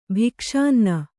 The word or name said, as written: ಭಿಕ್ಷಾನ್ನ